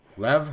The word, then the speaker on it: Lev: Lev, am I pronouncing her name right? His name? – Yep.